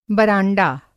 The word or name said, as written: बरांडा